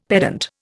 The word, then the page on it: pedant: Indian